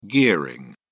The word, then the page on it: gearing: British English